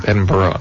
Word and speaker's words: Edinburgh: Edinburgh, they call it.